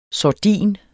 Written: sordin

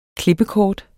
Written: klippekort

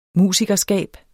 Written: musikerskab

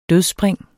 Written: dødsspring

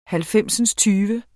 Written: halvfemsindstyve

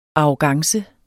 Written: arrogance

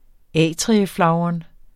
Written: atrieflagren

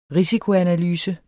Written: risikoanalyse

